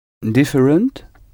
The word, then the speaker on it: different: Different. – Different. Different. En anglais, le T à la fin du mot se prononce. Different.